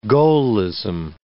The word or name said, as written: Gaullism